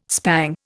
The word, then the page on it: spang: Indian